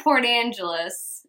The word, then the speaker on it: Port Angeles: When he goes to Port Angeles, is that how you say it? – Mm-hmm.